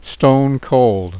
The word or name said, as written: stone cold